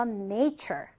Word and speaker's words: nature: 자, 지금 보면 여기서 처 발음이에요. 그래서 nature, nature 이렇게 읽어주시면 됩니다.